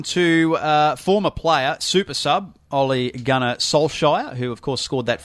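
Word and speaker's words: Ole Gunnar Solskjær: Ole Gunnar Solskjaer. – Solskjaer.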